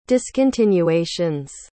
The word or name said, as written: discontinuations